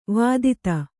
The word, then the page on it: ವಿದಿತೆ: Kannada